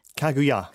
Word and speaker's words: Kaguya: Is that how you say it? – Kaguya. – Kaguya. Kaguya.